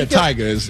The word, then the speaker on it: Tyga: Blige, and Tyga? Am I saying that right? – Yeah, Tyga.